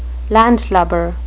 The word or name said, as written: landlubber